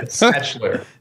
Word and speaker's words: Schnetzler: Schnetzler. In the middle, there's an N-E-T-Z.